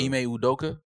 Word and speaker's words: Ime Udoka: Ime Udoka. – Ime Udoka.